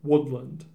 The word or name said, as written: Woodland